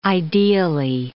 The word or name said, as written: ideally